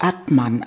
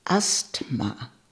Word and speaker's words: Atman: Er machte ein Wortspiel aus der Art, wie ich Atman aussprach, nämlich wie Asthma. Die korrekte Aussprache ist Atma.